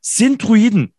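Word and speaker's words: Sint-Truiden: Wir schalten genauer gesagt nach Sintruiden.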